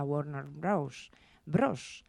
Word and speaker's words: Warner Bros.: Como era habitual en la época dorada de Hollywood, se rodó en California, en los estudios de la Warner Bros. Warner Bros., lo digo bien, que antes no lo he dicho bien.